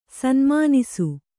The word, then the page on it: ಸನುಮಾನಿಸು: sanumānisu